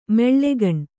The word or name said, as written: ಮಾಲಗಣ್ಣು